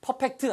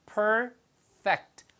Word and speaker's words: perfect: 발음은 perfect 아니고요. perfect. 위치아가 아랫입술에 가서 붙는 거예요. perfect.